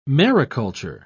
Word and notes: mariculture: British English

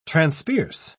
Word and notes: transpierce: British English

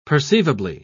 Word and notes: perceivably: British English

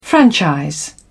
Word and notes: franchise: British English

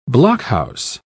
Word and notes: blockhouse: British English

